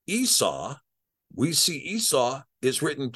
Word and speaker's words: Esau: And with this idea, so in this instance here, this is pronounced Esau. The ayin is pronounced A. And this shin is pronounced sin, sa, and the vav is pronounced with a shurig. So this is a su. So the Latins wrote this as e ye su.